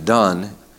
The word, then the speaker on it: Donne: You pronounce his name Donne.